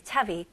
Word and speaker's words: chubby: 네, chubby, 통통한이라는 뜻을 가지고 있고요. 발음은 cha. 추 아니에요. 차비, 차비. 그래서 비가 두개 있는 거예요. 오케이? 그거 헷갈리지 않기 위해서 비가 하나였으면 추비나. 근데 비가 두 개니까 차비, 차비.